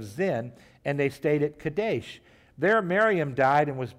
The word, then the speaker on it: Kadesh: That's how you say it in Hebrew, Kadesh.